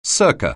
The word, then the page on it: circa: anglais